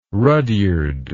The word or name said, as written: rudyard